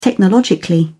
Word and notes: technologically: British English

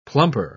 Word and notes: plumper: British English

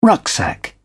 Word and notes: rucksack: British English

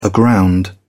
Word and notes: aground: British English

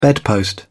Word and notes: bedpost: British English

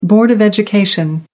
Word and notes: board of education: American English

